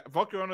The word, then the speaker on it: Volcarona: Uh, yeah, Volcarona is how you say it.